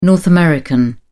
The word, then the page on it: north american: British English